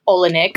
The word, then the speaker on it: Olynyk: Olynyk. Mm-hmm. I don't know how to say his name. – You said it right.